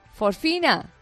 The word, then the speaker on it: fosfina: Un grupo de científicos de la Universidad de Cardiff ha encontrado en la atmósfera de Venus un gas llamado fosfino. No lo digo bien, ¿eh? Fosfina.